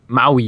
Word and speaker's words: Maui: So we kicked off this episode with a little flashback to Maui? Yeah, that's how you say it. – Maui, yeah.